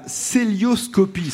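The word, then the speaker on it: cœlioscopie: E dans l'eau, comme on dit, c'est ça. C-O-E, avec le dans l'eau, L-I-O-S-C-O-P-I-E, la célioscopie, ça se prononce comme ça.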